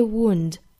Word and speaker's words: wound: Poison was in the wound. Was 和 in 之间有一个连读啊。 Was in the wound. 好， wound 这个单词我们看到是一个长元音 u 的发音。发完了 u 之后呢，去找一个前鼻音 n。 Wound 这样的发音。